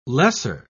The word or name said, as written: lesser